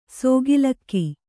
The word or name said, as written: ಸಕಾಲಿಕ